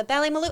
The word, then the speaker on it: Ballymaloe: Yeah, the Ballymaloe. And you said it right. Good job.